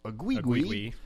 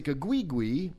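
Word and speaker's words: Aguigui: It's A-G-U-I-G-U-I. Agigui. Isaac Agigui.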